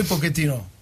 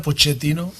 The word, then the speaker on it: Pochettino: Paris es un club que ha sido siempre uno de mis objetivos y el sueño de poder entrenar, porque entiendo que a veces la pronunciación en francés es difícil, ¿no? Pero como italiano que soy, Pochettino, pero en Argentina, Pochettino, ¿no? Por eso, para mí, cualquiera de las dos formas, me da igual.